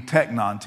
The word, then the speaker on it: teknon: So in the Greek, it would be, in New Testament form, would be teknon, T E K N O N.